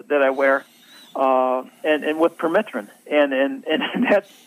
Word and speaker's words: permethrin: People think of spraying their pants and shoes with the permethrin. Is it? Am I saying it right? – Permethrin, yeah. – Yeah, permethrin.